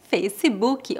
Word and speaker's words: Facebook: No Brasil, nós pronunciamos Facebook ou simplesmente Face. No português brasileiro, nós comumente adicionamos o som da letra I após consoantes mudas, com exceção das letras R e S. Por isso, dizemos Facebook.